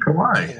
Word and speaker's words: Kawhi: So, what's interesting is that Kawi, how do you pronounce his name? Kawi? Kawi? – Oh, um, Kawhi. Kawhi.